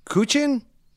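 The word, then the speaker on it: guqin: So this is, I believe it's pronounced Kuchin.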